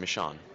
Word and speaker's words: Michonne: You did it right pretty much the first time, Michonne. – Okay. – Yeah. – I am a genius, fanboys, so listen up, everyone that keeps saying Michonne, it's Michonne.